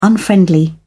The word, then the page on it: unfriendly: British English